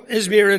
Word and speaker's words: Izmir: Is it Izmir? Is that right? Izmir? There you go. Izmir it is.